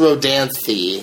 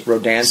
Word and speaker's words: Rodanthe: And it's pronounced Rodanthe. – Rodanthe. – So, thee. Thee. You could go something with that, with a thee sound.